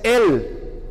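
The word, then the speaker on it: Él (אל): En hebreo, que es el idioma en que se escribió este pasaje, Dios se dice Él.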